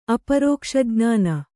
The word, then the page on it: ಅಪರೋಕ್ಷಜ್ಞಾನಿ: aparōkṣajnāni